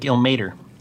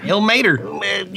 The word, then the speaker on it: Ilmater: It's like Ilmater. – Yeah. – It sounds, stank on it. – It sounds wrong, but it is that. – That's how you say it. – Ilmater? – Ilmater.